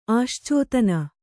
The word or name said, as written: ಆಶ್ಚೋತನ